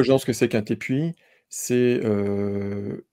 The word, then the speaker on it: Tepui: Alors, la prononciation que j'avais entendue, c'était Tepui.